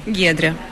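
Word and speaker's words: Giedrė: Donc je vais dire Guédret Bernotete Salfati. Donc ça, c'est la prononciation française. Maintenant, à une personne qui parle uniquement le lituanien, va-t-elle comprendre, quand j'aurai prononcé votre nom, que j'aurais dû prononcer ici? – Bien sûr.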